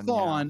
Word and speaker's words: Thrawn: Grand Admiral Thrawn.